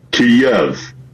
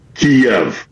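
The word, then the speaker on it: Kyiv: So do you put the Y at the end of the first syllable or at the beginning of the second syllable? So it is it Kiev or is it Kiev? Now, the Russian pronunciation is Kiev. The Ukrainian closest approximation that we can do as non-Slavic speakers is Kiev. So the Y ends at the first syllable.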